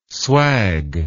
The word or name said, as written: swag